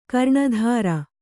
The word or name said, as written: ಕರ್ನೆತ್ತರ್